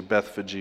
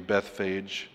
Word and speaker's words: Bethphage: And when they drew near unto Jerusalem and came unto Bethphage, unto the Mount of Olives, you can say Bethphage or Bethphage, different pronunciations.